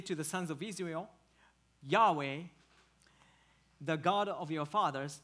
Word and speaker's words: Yahweh: So here God says that his name is Yahweh. The most English Bible translation puts the Lord in all caps here. And instead of transliterating the Hebrew four alphabets, that should be pronounced Yahweh.